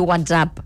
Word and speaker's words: WhatsApp: I WhatsApp, pronúncia aguda també, com a servei de missatgeria. – Aguda vol dir WhatsApp, eh? – WhatsApp. – Com si fos una paraula francesa.